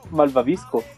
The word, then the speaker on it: malvavisco: Sí, hermano, es que estoy afuera con un malvadisco. ¿Cómo se dice? ¿Malvadisco o malvadisco? – Malvavisco.